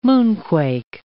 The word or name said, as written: moonquake